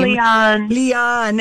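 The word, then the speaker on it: Liane: Leanne.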